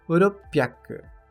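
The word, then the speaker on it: Uropyak (六百): Piak é uma leitura do kanji 100 usada no número 600, que é uropiak. Uropyak.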